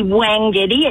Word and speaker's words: Wangetti: Wangetti or Wongetti? – Definitely Wangetti. It's W-A-N-G. Like Miss Vera Wang wouldn't want to be called Vera Wong. – Vera Wang is a designer of wedding dresses, and it is W-A-N-G, yes.